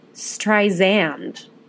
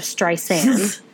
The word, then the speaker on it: Streisand: And she was upset to hear Siri pronounce her name Streisand instead of Streisand.